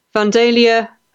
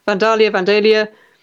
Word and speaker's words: Vandalia: Braden Victoria, hello from Vandalia, Ohio. Did I say that right? Vandalia.